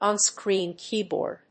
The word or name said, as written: onscreen keyboard